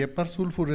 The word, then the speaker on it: Hepar sulphur: El medicamento que vamos a ver el día de hoy se llama Eparsulfur y Eparsulfur es la combinación de una flor de azufre con la cáscara de una ostra.